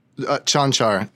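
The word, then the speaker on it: Čančar: It's Kankar? – Chanchar. Yeah, those C's Chanchar. Both have the upside down roof over them.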